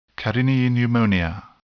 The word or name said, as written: carinii pneumonia